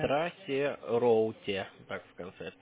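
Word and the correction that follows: traceroute: Трейс роут.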